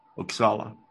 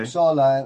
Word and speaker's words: Uppsala: Well, I'm living just outside of Uppsala. – Uppsala. Okay. – Uppsala. And Uppsala is the town with the oldest Swedish university. – Oh, Uppsala. Okay. My American pronunciation. Okay. You got the right pronunciation.